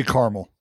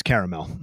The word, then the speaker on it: caramel: I say caramel because I'm normal. – Jay Hay? – I'm a big fan of pronouncing all the letters and all the syllables, so it's caramel.